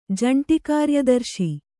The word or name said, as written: ಜಂಟಿಕಾರ್ಯದರ್ಶಿ